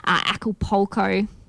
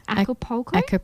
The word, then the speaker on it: Acapulco: He's a man on vacation in Acapulco. I did not say that right. – Acapulco.